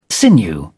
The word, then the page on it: sinew: British English